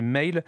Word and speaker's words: Mhaol: La prononciation c'est Mail, ça s'écrit M-H-A-O-L.